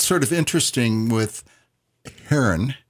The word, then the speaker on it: Heron: Heron. – Heron. And it's spelled like H-E-R-N, like the bird.